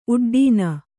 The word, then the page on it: ಉಡ್ಡಾಣ: uḍḍāṇa